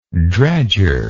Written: dredger

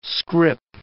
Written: scrip

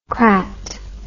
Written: crat

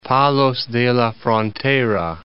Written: Palos de la Frontera

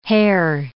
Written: hare

